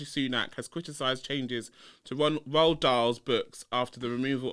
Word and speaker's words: Roald: Anyway, the final bit of word on the street was about, she's gonna tell me off for saying it wrong, but it's Ronald Dahl. – Roald Dahl, everybody. Roald Dahl. – Ronald Dahl. – Why are you saying Ronald? It's not fucking Ronald. Ronald McDonald.